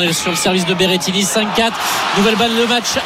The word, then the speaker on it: Berrettini: Berrettini. – Eh bien, elle l'a bien prononcé.